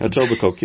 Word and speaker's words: Etobicoke: They totally, in Etobicoke, how do you say that? – Etobicoke, you got it.